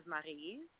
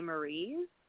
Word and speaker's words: Marie: Um, the French pronunciation is Marie, but a lot of English people tend to say Marie.